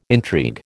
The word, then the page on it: intrigue: American English